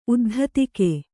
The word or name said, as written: ಉದ್ಧತಿಕೆ